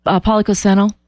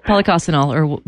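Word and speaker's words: Policosanol: Propoxyphenol? – Polycosenol. Yeah. Polycosenol.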